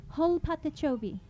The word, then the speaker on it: Hol Patachobi: So I'm going to need to teach you how to say these words so you can sing it with me. This means big alligator. Let me hear you say, Hol Patachobi. – Hol Patachobi.